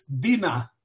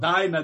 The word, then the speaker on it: Dina: The name's girl is, in my pronunciation, is Dina. Not Dinah. That sounds like dinosaur. It was Dina.